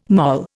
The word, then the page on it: moll: Indian